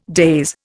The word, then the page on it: daze: Indian